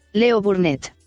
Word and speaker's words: Leo Burnett: Se pronuncia Leo Burnett.